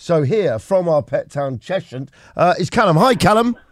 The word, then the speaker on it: Cheshunt: It's Cheshunt.